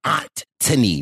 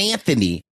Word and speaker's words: Antony: Now, the story really of this season is focusing on the oldest Bridgerton brother, Antony, not Anthony, Antony.